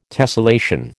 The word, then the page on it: tessellation: American English